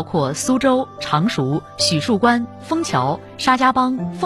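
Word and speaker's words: 浒墅关: 当时虎字和虎同音，第一个口误念成许树关的，相传是清乾隆皇帝。他在船头看到虎树关关旗时，虎字的三点水正好被遮住了，乾隆便说：“ 许树关到了，离苏州不远了。” 将错就错，这里就此念作许树关。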